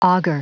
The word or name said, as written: auger